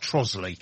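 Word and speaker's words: Trottiscliffe: I mean, Trosley's the worst by a million miles because it's spelt Trotterscliffe. – Yes. – Yeah. And pronounced Trosley.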